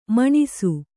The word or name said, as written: ಮೆಣಸು